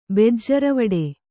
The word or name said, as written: ಬೆಜ್ಜರವಡೆ